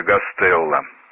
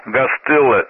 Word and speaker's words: Гастелло: Фамилию Гастелло стали произносить на московский манер Гастелло.